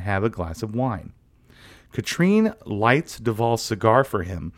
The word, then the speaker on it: Catherine: Later at a bar, Red is having a drink as Duval and his lady, Katrine. I'm going to try really, really hard to remember Katrine. – Katrine, yeah. – Because it's spelled in every single text and in the credits as Catherine.